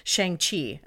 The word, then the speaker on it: Shang-Chi: Songs inspired by the creator of Shang-Chi.